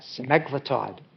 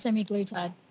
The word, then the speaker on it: semaglutide: The only thing is I went to a conference recently and they were talking about semaglutide. Does anyone know what semaglutide is? – Yeah, semi-glutide. – Yeah. Apparently we've all been pronouncing it wrong.